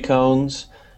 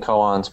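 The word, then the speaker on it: koans: There's uh, Ruby cones, uh, which is spelled Ruby coons, but and I've been saying coons, but I guess it's cones.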